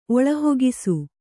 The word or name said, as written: ಒಳಹೊಗಿಸು